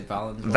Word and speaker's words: Valenzuela: I usually say Valenzuela.